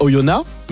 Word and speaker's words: Oyonnax: Parce que à côté de Bourg, il y a Oyonnax, mais oui. ça s'écrit avec un X à la fin. Si tu vas là-bas et tu dis Oyonnax.